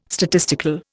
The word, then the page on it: statistical: Indian